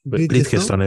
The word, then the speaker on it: Bridgestone: Eh, eh, dilo tú porque a mí no me sale la pronunciación de Bristol. Bridgestone, voy a decir.